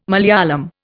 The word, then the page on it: Malayalam: Indian